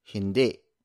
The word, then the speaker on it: hindi: Meanwhile, the Tagalog word for no is hindi. Hindi. It is spelled as H-I-N-D-I. Kind of like Hindi, the Hindi language. But in Tagalog, it's no. So we pronounce it as hindi.